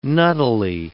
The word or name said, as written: nuttily